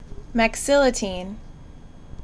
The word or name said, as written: Mexiletine